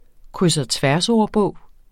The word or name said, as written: kryds